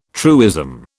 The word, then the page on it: truism: British English